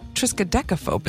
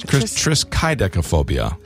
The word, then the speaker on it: triskaidekaphobia: I've always said triskaidekaphobia. – It's triskaidekaphobia. – Triskaidekaphobia. – I've been saying it wrong, too? – You absolutely have. Triskaidekaphobia is your word for today.